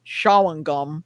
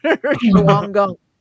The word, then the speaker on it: Shawangunk: There's the, you're not going to pronounce this right, Shawangunk. Shawangunk.